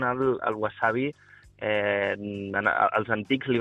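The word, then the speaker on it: wasabi (山葵): O sigui, llegeixen Yama Aoi, però diuen wasabi.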